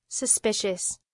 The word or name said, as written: suspicious